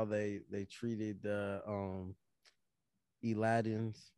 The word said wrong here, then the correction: Eldians: or Elidins. How how do you say it? Eldians. Eldians, yeah.